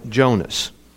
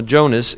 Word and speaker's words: Jonas: Now Jonas is Jonah. It's the, just you're going from a Hebrew pronunciation or spelling to a Greek, hence the difference there.